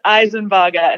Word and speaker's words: Isenbarger: It is Isenbarger, and it's a bit of adjustment for me coming from Grant.